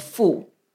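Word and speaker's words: fu (妇): So it's pronounced as fu. And the character of a woman pronounced as nu. So now the Chinese word for female in general, they say fu nu together.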